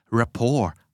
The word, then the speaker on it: rapport: rapport R A P P O R T อ่านว่า rapport